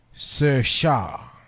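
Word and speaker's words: Saoirse: You pronounce that Irish name Sir Shah. – Ah, thank you. – Spell it again, Gavin. – S-O-I-R-S-E.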